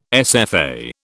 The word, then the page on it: SFA: British English